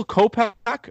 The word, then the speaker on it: Kopech: Is it Michael Kopech or is that how you say it? – Yep.